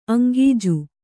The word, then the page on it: ಅಂಗೀಜು: aŋgīju